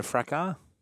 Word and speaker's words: fracas: Sorry, isn't a fracas? – Yeah. – Isn't the S silent? – Yeah, it's a silent S.